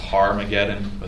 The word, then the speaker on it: Armageddon: And that's the Armageddon, or maybe you've heard it pronounced Harmageddon with an H.